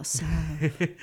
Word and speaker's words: salve: I guess you're not supposed to pronounce the L. A salve.